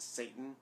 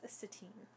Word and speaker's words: Satine: Excuse me, to it's pronounced Satan. – It's pronounced Satine.